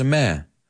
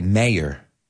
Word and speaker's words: mayor: The mare has a mare. The mare. But we don't. In the States, we say mayor. So they say it as one syllable, mare. We say mayor.